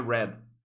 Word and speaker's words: read: Então, assim, você escreve read, né? Só que você pronuncia read. E o participe é a mesma coisa. Então, assim, é read, read, read. Né? – É... Só que se escreve tudo do mesmo jeito, né? Se escreve tudo do mesmo jeito. Então, a, única coisa que vai diferenciar é a, é a fala, né? To read, read é, no, no, no, presente, read no passado e o participo também é read, mas todo, tudo se escreve do mesmo jeito, mesmo formato.